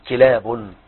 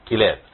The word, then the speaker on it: كلاب: نعم، كلاب. – كلاب. – نعم، كلاب.